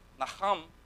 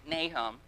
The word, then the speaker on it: Nahum: Now, the ancients would have pronounced it more like Nahum, but, you know, we say Nahum, so turn to Nahum.